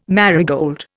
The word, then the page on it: marigold: Indian